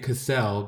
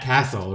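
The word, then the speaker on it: Cassel: It's actually Matt Castle, okay? – No, Castle is what you build, like sandcastle. – I know, but his name is pronounced Matt Castle, not Cassell.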